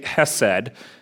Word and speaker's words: chesed: I'm not going to clear my throat every time because I might lose my voice. I'm going to say chesed is the way that I'll pronounce it.